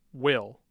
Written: Will

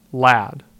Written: Ladd

